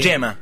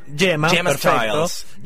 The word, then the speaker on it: Gemma: Con G. Sí. Yema. Yema, Styles. Yema, Styles es eh, periodista, escribe para MTV UK.